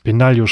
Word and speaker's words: Benaglio: Ähm, Benaglio spricht man das aus, richtig? – Ja.